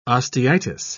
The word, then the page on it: osteitis: British English